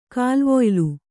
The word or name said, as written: ಕಾಲ್ವೊಯ್ಲು